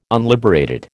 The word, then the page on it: unliberated: American English